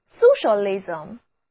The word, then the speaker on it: socialism: 我们中国是社会主义国家，这个社会主义就是 socialism. Socialism. S-O-C-I-A-L-I-S-M 后缀 I-S-M 是一个名词后缀，读作 ism。